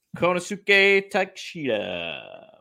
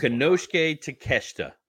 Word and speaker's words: Konosuke Takeshita: Officially official against Konosuke Takeshita. What do you think of this? – Konosuke Takeshita.